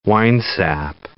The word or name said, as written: winesap